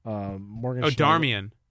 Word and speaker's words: Darmian: Marcus Rojo, Darmian.